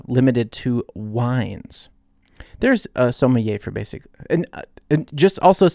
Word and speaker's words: sommelier: This is a very, very French word, and it's not sounding even remotely the way that it is spelled. It is spelled S-O-M-M. You still with me? S-O-M-M-E-L-I-E-R. Sommelier.